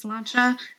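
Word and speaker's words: Sláinte: You see it on the sides of, like, if you're at an Irish bar, it says Slancha. It's S-L-A-I-N-T-E.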